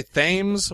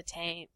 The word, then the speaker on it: Thames: I want to say Thames, but I feel like that's wrong. – It's spell it out. The Tame. – T-H-A-M-E-S.